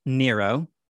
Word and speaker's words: Nero: His name in Latin is pronounced Nero. – Yes. – His name in Greek is said differently. It's Neron.